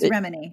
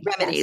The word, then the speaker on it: Remini: like Leah Remini or Remini? – I think it's Remini. – Remini, yes. Leah Remini.